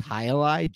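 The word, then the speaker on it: Jai Alai: The third one for me would probably be, I never know how to pronounce it, but Jailai, I think it is. Jailai, J-A-I space Alai.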